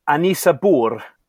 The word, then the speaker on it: Ynysybwl: This is Y-N-Y-S-Y-B-W-L. – Have a go at pronouncing that. – Apparently, it's pronounced Anisabur.